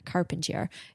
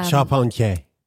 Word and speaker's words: Charpentier: I know that's probably not how you pronounce Carpentier in French. – Charpentier.